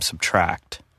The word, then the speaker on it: SBTRKT: So he goes by the name Subtract, which is spelled S-B-T-R-K-T.